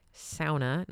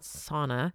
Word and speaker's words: sauna: That's how you pronounce it, by the way. Sauna, not sauna. It is a Finnish word.